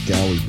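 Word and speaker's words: Gaudi: It's called the Gowdy Bar, so it's G A U D I, but I think he pronounces it Gowdy Bar.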